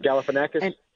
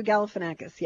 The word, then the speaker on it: Galifianakis: And the guy that was taken to the snake restaurant, which is Zach, Galifianakis or whatever, I don't even know how to say his name. – Galifianakis? – Thank you, Galifianakis, yeah.